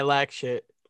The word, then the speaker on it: Lack5HT: Am I saying that right? Lack Shit. – Yeah, Lack Shit.